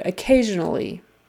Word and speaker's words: occasionally: Another long word, occasionally. So for this one, the accent is on the second syllable. Occasionally, occasionally. And that S in the middle is a Z sound.